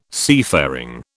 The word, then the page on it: seafaring: British English